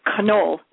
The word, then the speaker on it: Canole: She says people pronounce it in a variety of ways. She said people that love the Italian pastries call it cannoli, but it's Cannole. I think it's Irish or Scottish.